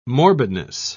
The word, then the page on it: morbidness: British English